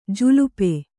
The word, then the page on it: ಜುಲ್ಪಿ: kannada